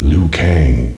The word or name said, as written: lui kang